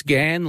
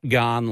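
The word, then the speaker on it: Ghan: So I do appreciate it, and I'll never forget that it's Gan like Nan, not Garn like I've gone on the Gan.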